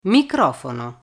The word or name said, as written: microfono